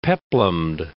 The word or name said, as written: peplumed